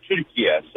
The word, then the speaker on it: Türkiye: The way Turkish people pronounce it is actually the way it's said, Türkiye.